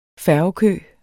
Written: færgekø

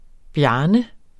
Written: Bjarne

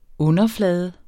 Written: underflade

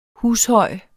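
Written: HUSHØJ

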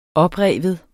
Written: oprive

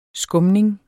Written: skumning

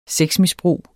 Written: sexmisbrug

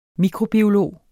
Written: mikrobiolog

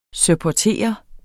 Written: supportere

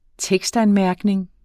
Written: tekstanmærkning

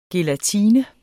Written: GELATINE

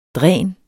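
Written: DRÆNET

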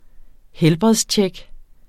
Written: helbredstjek

